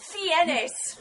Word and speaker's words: Fiennes: It's pronounced Fiennes.